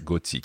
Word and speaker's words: gothique: And watch the H after the in cathédrale and in gothique, because obviously in English you would say cathedral and gothic. Okay, but in French it's silent.